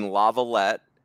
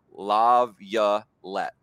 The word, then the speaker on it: LaViolette: It's Lav, like lavalier, yuh's guys, all that, you know, LaViolette. So I think I've turned a corner. I've been really struggling with your name, Jace LaViolette.